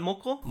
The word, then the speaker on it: moko: With, as far as moko goes, am I saying it right? Moko? – Moko, yep.